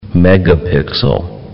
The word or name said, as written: megapixel